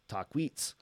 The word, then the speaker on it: Taquis: the camp of the Taquis, pronounced the same as Taquits, known to the Chahula of Aguilla Caliente.